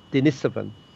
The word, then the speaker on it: Denisovan: And that's the correct way of pronouncing it, by the way, Denisovan.